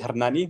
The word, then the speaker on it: Hernani: Yes, kind of correct. Yeah. It's Hernani, but Hernani, you know, sorry.